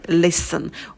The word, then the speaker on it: listen: Например, listen, ударение на первый слог, два слога, то ничего удваивать не будем.